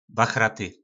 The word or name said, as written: bachraty